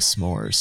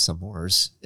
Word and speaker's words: s'mores: So I don't know if you guys how you pronounce it, but I thought it was some mores, and she said no s'mores. You like you you blend them together.